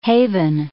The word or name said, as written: haven